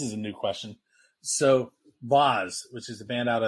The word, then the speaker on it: Vase: It's spelled V-A-S-E, but it's pronounced Vaz.